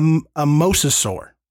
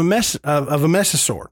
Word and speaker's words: mosasaur: Unlike Nessie and Champ that are often described as being a plesiosaur, Smeddy is more like a mosasaur. And I hope I'm saying that right. – Mm-hmm. – I'm just pronouncing it as it's spelled. – Yep. But you got it.